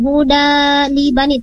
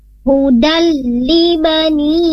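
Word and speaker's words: hudal li bani (هدى لبني): Harusnya bacanya tidak dipanjangin huda li bani, tapi langsung ke huruf lam. Namanya hukumnya idgham bila gunnah hudal li bani langsung huruf lamnya.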